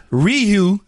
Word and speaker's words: Ryu: Ryu, did go. I say it right? Yeah. yeah.